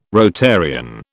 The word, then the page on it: Rotarian: British English